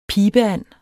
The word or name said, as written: pibeand